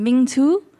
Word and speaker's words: Mintu: es ist nicht mal die richtige Aussprache, aber ähm, also es gibt eine richtige Aussprache, eine richtige vietnamesische Aussprache. Wenn man mich richtig ausspricht, wäre das ein Ming Tu. Sind zwei zwei ähm, Sound so Sachen, die es im Deutschen nicht gibt